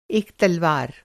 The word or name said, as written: तलवार